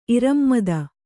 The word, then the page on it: ಇರಮ್ಮದ: irammada